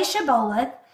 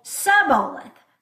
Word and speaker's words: Shibboleth: Shibboleth, and then they would say Shibboleth without the sh. So it wasn't sh, it was just s.